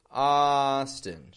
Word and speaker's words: Austin: And notice again the contrast in the syllable stress. Austin, strong, weak.